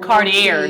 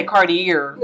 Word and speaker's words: Cartier: Like you gotta flash, you gotta be flashy wearing Louis Vuitton and Cartier. Don't even say it right. You gotta say Cartier.